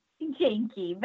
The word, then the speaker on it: Janke: You're just going to have to say your last name for me again, but I am not saying it right. I'm in the ballpark. Is it Janke? Janke. – Janke, but that's Janke. – Okay.